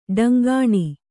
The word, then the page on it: ಢೆಂಕಣ: ḍheŋkaṇa